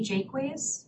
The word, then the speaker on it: Jaquays: Julie Jakeways on behalf of the respondents. – State your name again, please. – Julie Jakeways. – Spell that, please. – J-A-Q-U-A-Y-S.